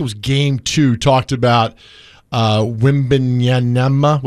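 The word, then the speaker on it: Wembanyama: So Wimbenyama. – Yeah, that's good. – Wimbenyama.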